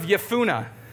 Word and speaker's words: Jephunneh: Caleb, son of Jephunneh. Yes, that is said correctly. That's a silent J, as they say.